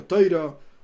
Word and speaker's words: Torah: Si alguno se marea porque a veces digo Torah, a veces digo Toiro, Ok, simplemente la pronunciación, pero al final el, asunto es el mismo.